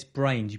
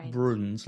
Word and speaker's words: Brains: It's Brains. You pronounce it Brains. Damien Brains. Can you remind me if I ever say Bruins like he's some kind of Scottish, like, I don't know, guy up in the...